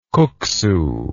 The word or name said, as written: kuksu